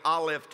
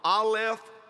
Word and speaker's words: Aleph: In Hebrew, he was saying, I am the Aleph Tav. Aleph Tav. Say that out loud. Aleph Tav. You spell it A-L-E-P-H. Pretend that P-H is an F. To say it right, Aleph.